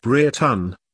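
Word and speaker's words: Brereton: The Bree Wrectons, pronounced Breton, originated in Bree Wrecton, in East Cheshire, although there were other branches of the family.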